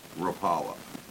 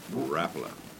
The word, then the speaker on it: Rapala: So, one of the things about it is everybody's heard of Rapala, but that's not how you pronounce it, is it? – Rapala. – Rapala.